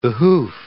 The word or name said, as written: behoof